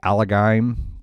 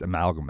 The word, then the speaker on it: Amalgam: And there was a series called Infinity Warps where they kind of basically did I used to always say the Allegheim. And I guess it's not pronounced Allegheim, but I have no pronunciation key in front of me. Amalgam, I think it's Amalgam. Amalgam is the actual word.